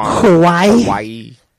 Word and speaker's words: Huawei: Because didn't they arrest the CEO of the, how do you pronounce that company? Huawei? – Huawei.